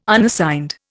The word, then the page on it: Unassigned: Indian